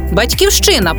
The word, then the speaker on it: Батьківщина: Батьківщина пишемо з великої, якщо вкладаємо урочистий патріотичний зміст, чи маємо на увазі країну, в якій народилися, і громадянами якої є. Вимовляємо з наголосом на першому або передостанньому складі.